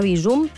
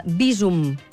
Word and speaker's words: Bizum: Doncs mira, jo feia bandera de dir-ne Bizum, així aguda, pronúncia aguda, però la recomanació en aquest cas és Bizum, plana.